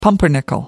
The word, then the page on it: pumpernickel: American English